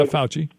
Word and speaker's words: Fauci: I just wanted to say that I thought Dr. Anthony Fauci, however you pronounce his name. – Yeah, Fauci.